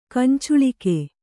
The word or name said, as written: ಕಂಚಳಿಗೆ